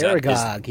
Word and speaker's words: Aragog: Aragog is Aragog,